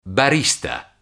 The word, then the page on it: barista: Italian